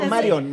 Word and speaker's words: Marion: No, Marion, Marion. Marion Reimers, Marion. Exacto, sí. Entonces una palabra grave que termina en N no lleva acento.